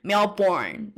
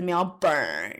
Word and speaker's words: Melbourne: 那既然讲到维州的话，就跟大家顺便分享，相信大家都知道墨尔本就是 Melbourne，但是因为很多像我刚刚说，比如说美国来呃度假的，或是就比较不知道澳洲人喜欢乱发音，没有，就没有照着音标发音的，就是这种。习惯的话，很多人会称 Melbourne 为 Melbourne 这样子，但是其实不是，是 Melbourne 那样。